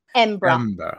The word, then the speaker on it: Edinburgh: I'm going to correct your pronunciation already, but It is, of course, Edinburgh. It's actually only got three letters, five letters, if you think about it. E-M-B-R-A. Edinburgh. There you go.